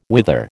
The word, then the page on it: wither: American English